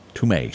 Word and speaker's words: Tomei: Marissa Tomei. – Tomei.